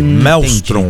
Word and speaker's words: Maelstrom: É, na verdade, a gente errou ontem, viu? Fui lembrado da pronúncia ontem na coletiva. É Operação Maelstrom. – Ah, Maelstrom. – É, o A dá uma sumida ali. Maelstrom.